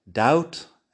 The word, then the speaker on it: doubt: Como si no estuviera ahí. Doubt, debt. Tienes la D, tienes el sonido de la vocal y tienes la T la B se escribe pero no se pronuncia.